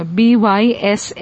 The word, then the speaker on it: Bysshe: Bysshe.